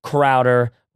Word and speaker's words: Crowder: Search for Courage and Clarity, a new podcast from Fizzle.fm and Steph Crowder, rhymes with chowder.